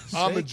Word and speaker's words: almond: You just say almond. You don't even really have to pronounce the L. – Just say the L. Yeah, you don't really hear the L in there.